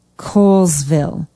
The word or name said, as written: kohlsville